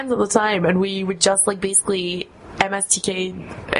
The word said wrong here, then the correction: MST3K: I can't even say it properly. – MST3K.